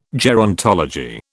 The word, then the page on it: gerontology: British English